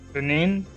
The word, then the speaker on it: Benin: 1% Benin. I think I'm saying it right. In Togo. – Benin.